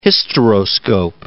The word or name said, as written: hysteroscope